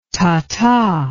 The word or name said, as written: tata